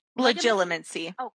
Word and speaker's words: Legilimency: The whole time I was reading this, I kept saying, is it, can he do, how do you pronounce it? – Legilimency.